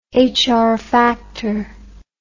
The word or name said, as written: Hr factor